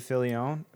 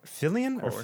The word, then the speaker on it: Filoni: Directed by Dave Filoni. – Filoni. – Filoni. It's because we pronounce it different here. – Filoni. – Yeah. – In Canada, it's different. There's an extra I.